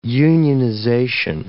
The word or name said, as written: unionization